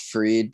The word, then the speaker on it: Fried: And, it's actually pronounced Freed, but like it's spelled Fried, I believe.